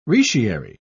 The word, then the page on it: retiary: British English